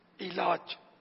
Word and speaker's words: علاج: علاج, علاج, درست تلفظ ہے علاج, عین کے نیچے زیر.